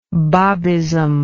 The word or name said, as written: Babist